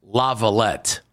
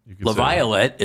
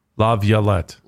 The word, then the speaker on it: Laviolette: He pronounces a Y there. – Laviolette.